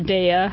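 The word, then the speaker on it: Daiya: We use the new Daya. – Oh. – Or Daya. – Oh. – Do you know how that's pronounced? – Daya? People say both.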